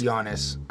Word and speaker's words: Giannis: It's Giannis. – I think that's a weird way to spell Giannis. So the G is silent.